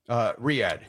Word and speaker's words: Riyadh: Is it Riyadh? – Riyadh.